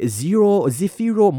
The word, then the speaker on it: Zefiro: It's spelled Z-E-F-I-R-O.